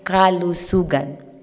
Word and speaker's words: Kalusugan: Perhaps I will teach you how to pronounce it so you can bring home one Filipino word, Kalusugan. The root word is lusug, which means health.